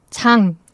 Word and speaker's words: 장: Today's Chinese character is 장.